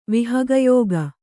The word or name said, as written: ವಿಹಗಯೋಗ